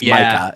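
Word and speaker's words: Maika: Her name is M-A-I-K-A. I believe it's Micah is how you pronounce it. It's just spelled uniquely.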